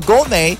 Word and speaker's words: gourmet: G-O-U-R-M-E-T. G-O-U-R-M-E-T. Now, you must have noticed something. The T in the word is silent, right? So the word is gourmet. Gourmet. That's how to say the word. And the second word is the word glutton. Glutton. And the word is spelled G-U-L-T-T-O-N.